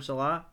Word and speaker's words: Saleh: They believe in Robert Saleh. How do you say his name? Saleh, corrected.